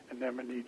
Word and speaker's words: anemone: And somebody explained to me or suggested, well, if you think of any money, then you can remember how to pronounce the word. So now it's just anemone just rolls off of my tongue.